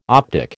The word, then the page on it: optic: American English